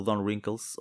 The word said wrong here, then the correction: Don Rickles: ou Don Rickles, que eu tenho a mania de meter um N a mais é, na pronúncia, eh, era um gajo que me enchia as medidas porque ele era assim no dia a dia.